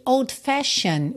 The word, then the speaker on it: old-fashioned: Atenção que old-fashioned, você pode falar como se tivesse escrito fashion. Mesmo. Por quê? Porque o E não é pronunciado, certo? E o D pode ou não ser pronunciado, a depender de uma série de coisas, mas aqui, como a gente tem o N antes do E e outro N já na próxima palavra, a gente acaba juntando no N e ignora o D. Então, fica old-fashioned notion, tá? But a sweet old-fashioned notion. E eu pintei esse T-I-O-N aí para você lembrar que essas terminações de palavras T-I-O-N se pronuncia shun, shun, tá?